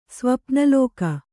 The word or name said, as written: ಸ್ವಪ್ನಲೋಕ